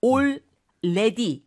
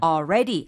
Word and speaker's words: already: So you can just drop the L. It's already been a long day. – It's already been a long day. – 예스. – 네. 그러니까 already 이러면 안 된다는 거죠. 네. 그러니까 L 발음, AL에 너무 이제 신경 많이 쓰시지 마시고 어차피 뒤에 R이 있으니까 That's right. – already 하고 바로 R로 발음을 넘어가셔도 좋겠습니다.